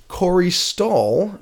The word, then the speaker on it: Corey Stoll: Corey Stoll, am I saying that right? – Yeah.